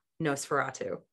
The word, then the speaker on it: NOS4A2: Yeah, like in Nosferatu, which if you're not familiar with that book, it's a license plate, so that's how you pronounce it, but it's NOS4A2. Nosferatu.